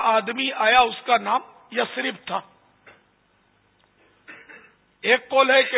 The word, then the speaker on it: یثرب: یثرب اصل تلفظ کا یثرب نہیں ہے، یثرب.